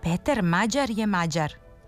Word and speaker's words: Mađar: Tako bi prezime Mađar trebalo da se akcentuje u srpskom jeziku, isto kao i naziv za žitelja Mađarske. Dakle, kratkouzlazni akcenat na prvom slogu i dužina na sledećem.